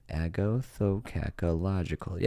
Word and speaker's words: agathocacological: Am I saying this right now? I just looked it up. Agathocacological. Yeah. Nailed it.